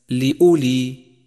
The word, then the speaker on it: لأولي: خامسا، تأمل الواو الرمادية في كلمة لأولي لأولي، فإنها لا تلفظ، وقد ميزها مصحف التجويد بهذا اللون الرمادي إشارة إلى عدم لفظها لأولي وليس لأولي، فهو خطأ شائع.